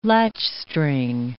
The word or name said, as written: latchstring